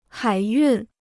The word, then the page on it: 海运: hǎi yùn